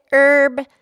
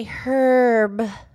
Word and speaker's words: herb: Some people say herb, and some people say herb. – Well, yeah, it is spelled with an H, H-E-R-B. – Yeah, and you can pronounce the H if you want, or you don't have to. Either way, you can do it either way.